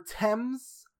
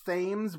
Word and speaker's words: Thames: Second of all, it's pronounced the River Thames. Not Thames, whatever I said.